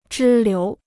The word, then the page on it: 支流: zhī liú